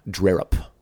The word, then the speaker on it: Drerup: Drerup.